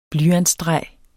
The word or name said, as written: blyantstreg